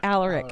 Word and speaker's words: Alaric: Um, is it Alaric? is that how Alaric, you pronounce it? Yeah. Alaric. – Alaric.